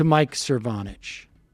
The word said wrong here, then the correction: Cernovich: Am I pronouncing his name right? – Mike Cernovich. – Cernovich.